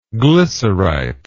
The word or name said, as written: glycerite